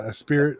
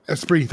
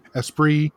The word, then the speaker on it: Esprit: So seeing them all, seeing the old Gap logo, seeing Esprit. I can't even say it right. – Esprit. – Thank you. Esprit.